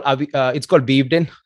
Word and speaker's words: Weavedin: It's called Weavedin. So it's a mix of, I would say. So how do you spell that? – W-E-A-V-E-D-I-N. – V-E-N? – W-E-A-V-E-D-I-N. – V-E-D-I-N. Weavedin. Yeah. Got it.